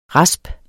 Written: raspes

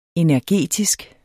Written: energetisk

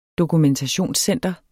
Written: dokumentationscenter

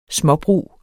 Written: småbrug

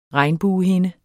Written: regnbuehinde

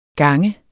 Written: gange

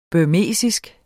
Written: burmesisk

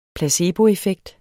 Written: placeboeffekt

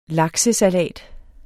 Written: laksesalat

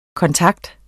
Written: kontakt

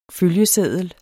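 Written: følgeseddel

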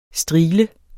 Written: STRIGLEN

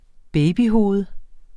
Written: babyhoved

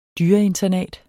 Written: dyreinternat

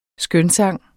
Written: skønsang

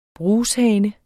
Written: brushane

